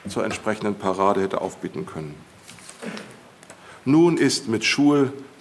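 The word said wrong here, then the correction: Choules: so hieß das dann mit Claude Stanley Jules, muss man ihn aussprechen, nun ist mit Jules auch ein Teil deutscher Geschichte gestorben, titelte die Bild-Zeitung am 5.5.2011.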